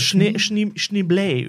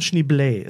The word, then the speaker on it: Schneebly: Ja, er heißt Schneebley übrigens. – Schneebley. – Es wird ausgesprochen Schneebley. Auch oft zitiert immer noch.